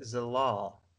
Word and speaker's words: Zalal: I'm going to butcher this guy's name, man. Um, uh, gosh, Ye- oh boy, Youssef Zalal, Zalal. How you want to go with that? Z- Zalal. – Youssef Zalal.